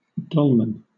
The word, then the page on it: dolman: British English